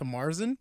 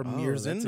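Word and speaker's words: Märzen: I finished it off with 30 seconds to Marzen, or Mirzen, depending on how you want to pronounce it.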